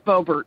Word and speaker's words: Bobertz: And is your last name pronounced Bobertz or Bobertz? – It is Bobertz. – It is Bobertz. – You got it right.